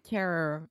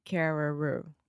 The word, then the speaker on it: Carreyrou: I believe his name is John Carreyrou. – Carreyrou. It looks like Carrera, but it's Carreyrou.